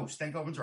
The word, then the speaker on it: Stankoven: And I think you've got number four and I've got him at number five, and that's Logan Stankoven. – I'll tell you. – Am I saying it wrong? – No, Stankoven's right.